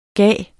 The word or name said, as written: given